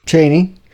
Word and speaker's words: Chaney: Chaney. – Chaney. – Yeah, it's like Dick Cheney.